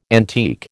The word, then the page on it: antique: American English